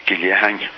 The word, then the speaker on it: Gilzean: And his name really should have been pronounced Gillehang, well, which is again a Scots Gaelic name, and that introduces a Z, so that'll help you.